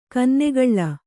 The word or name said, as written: ಕನ್ನಗಳ್ಳ